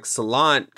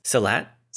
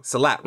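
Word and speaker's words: Silat: And like Rama using like Salat kind of speaks to who he is. – Salat. – I said it wrong. Salat, right? Yeah.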